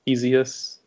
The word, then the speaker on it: Isaias: Yeah, so it's spelt I-S-A-I-A-S. And I think originally some of us thought it was Isaias or Isaias, but it's actually pronounced Isaias, which, as Ben said, is the Spanish-Latin derivative of the Hebrew name Isaiah.